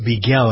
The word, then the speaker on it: Beigel: Scott Beigel, I believe is how you pronounce his name, B-E-I-G-E-L, he was 35 years old.